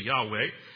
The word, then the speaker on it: Yahweh: Jehovah, sometimes used with the letters YHWH, pronounced in English occasionally Yahweh,